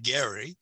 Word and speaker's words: Gerry: A well-known politician in the 1800s named Elbridge Gerry, or as he pronounced it, Gerry.